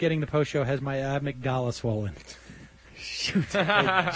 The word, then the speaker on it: amygdala: It's amygdala.